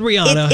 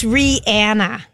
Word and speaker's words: Rihanna: We've always said Rihanna. – It's Rihanna.